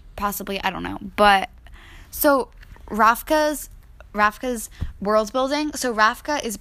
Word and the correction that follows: Ravka: It sounds like you're saying Rafka. – Ravka? What? How do you pronounce this, Easy? – It's like that. It's just you sound like you're saying Rafka when it's Ravka.